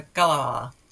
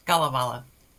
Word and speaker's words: Kalevala: The Finnish national epic is the Kalevala. I'll say that again. The Kalevala.